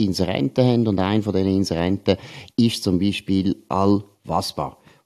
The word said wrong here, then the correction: Alva Spa: Oder besser gesagt, Alva Spa. Ich habe es eben falsch ausgesprochen, tut mich entschuldigen für das, ist ja logisch.